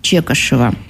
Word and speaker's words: Чекашев: Из личного архива Владимира Чекашева, участника, правильно говорю? – Да. – Участника тех событий. – Чекашев. – Да, правильное ударение на фамилию.